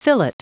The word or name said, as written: fillet